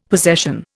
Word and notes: possession: Indian

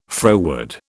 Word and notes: froward: British English

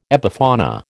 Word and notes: epifauna: American English

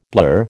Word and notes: blur: American English

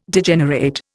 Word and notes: Degenerate: Indian

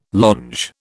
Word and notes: longe: British English

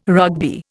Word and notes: Rugby: Indian

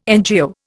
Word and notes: NGO: Indian

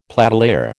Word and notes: platelayer: American English

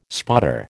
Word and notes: spotter: American English

